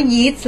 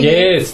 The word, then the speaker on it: Yeats: มันไม่อ่านว่า Yeats หรอมันอ่านว่า Yeats